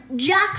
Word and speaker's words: Jacques: Yeah, but before that, Lemony Snicket's in-universe brother, Jock Snicket, as in that cooking show guy, his first name is pronounced as in that cooking Jacques Pepin.